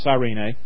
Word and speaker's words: Cyrene: As they led him away, they seized a man, Simon of Cyrene, sometimes pronounced Cyrene, who was coming from the country, and they laid the cross on him and made him carry it behind Jesus.